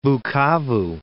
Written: Bukavu

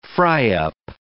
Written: fry-up

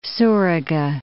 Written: Suruga Bay

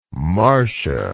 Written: marcia

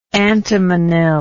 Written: antimonyl

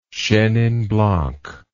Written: chenin blanc

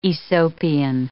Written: Aesopian

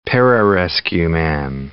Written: pararescueman

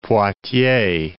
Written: Poictiers

